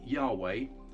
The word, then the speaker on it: Yahweh: Now, people have tried to sort of fill in the gaps, and the best guess is that that's what the name would have looked like, Yahweh, and that's how we pronounce it, but we don't know if that's the way in the original Hebrew.